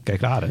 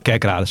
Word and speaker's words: Kerkrade: Is de, jij zegt echt de klemtoon anders bij... Kerkrade, sorry. Jij hoorde het zelf ook. Ja, oké. – ik was benieuwd of dat, wat zeg jij? Of dat het misschien goed kerkrade.